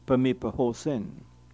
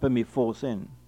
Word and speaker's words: Pemipahosin: I should remark that PH is never pronounced as F, but is pronounced separately as if it were PE and then HOSIN, Pemipahosin, not Pemifosin.